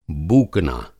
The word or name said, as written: बूकना